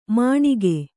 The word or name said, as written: ಮಣಿಗೆ